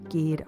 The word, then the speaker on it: Gide: You know, there's this marvelous quote from um, Andre Gide.